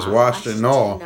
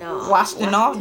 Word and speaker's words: Washtenaw: It's Washtenaw. – Washtenaw. Did I say it wrong? – No, you said Washtenaw.